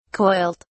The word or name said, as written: coiled